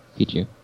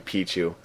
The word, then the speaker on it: Pichu: Pichu. – Excuse me, Pichu. That extra syllable screwing me up.